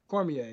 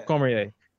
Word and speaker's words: Cormier: Oh, pronounce Cormier. his last name. Cormier. Yeah. – Cormier.